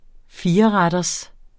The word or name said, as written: fireretters